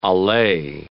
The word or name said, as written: allee